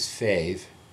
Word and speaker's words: five: And there, the Dutch number for five is fev. And in Dundee, it's fev. You know, so it's een twa fower fev. And so... That would be the one of the differences anyway on the vowel sound.